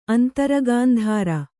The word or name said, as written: ಅಂತರಗಾಂಧಾರ